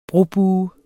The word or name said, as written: brobuer